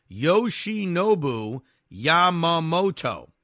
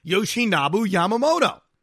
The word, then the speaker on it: Yoshinobu Yamamoto: Just pronounce each syllable as it's spelled. Yoshinobu Yamamoto. Well, hold on a second. Haven't I gotten that right all year? Yoshinobu Yamamoto.